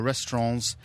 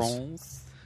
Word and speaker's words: restaurant: And while we were in there, we were trying to think of alternate names for restaurants in BBC compounds and festivals. – Restaurants? – I got told off the other day by someone for pronouncing the T at the end of restaurant.